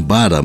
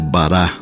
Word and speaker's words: bará: Alguns pronunciam bara, mas o certo é bará.